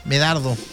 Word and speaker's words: Medardo: Bueno, el refrán popular dice: si llueve por San Medardo, ¿cómo se dice? Medardo, ¿cierto? Medardo, sí.